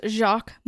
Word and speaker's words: Jacques: This man was Jacques Mossler. Now, that last name should sound familiar. – Yeah. – So stow that away. I've seen it pronounced like Jax, Jackies, everything like that. It's Jacques, right? J-A-C-Q-U-E-S.